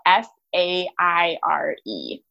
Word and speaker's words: Faire: It's called Faire. It's spelled F A I R E.